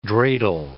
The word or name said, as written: dreidl